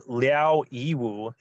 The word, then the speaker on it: Liao Yiwu: by the author and um, reporter Liao Yiwu.